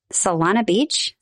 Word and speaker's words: Solana Beach: Then we're going to be near San Diego in Solana Beach. Is that how you say it? – Yep.